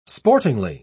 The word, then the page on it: sportingly: British English